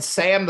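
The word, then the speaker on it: SA5M: And listeners, if you're curious why we keep referring to her as Sam, the Five is silent, it's because this woman's name is spelled S-A, the number five, M. – But the five is silent. – And it is pronounced Sam, the Five is silent.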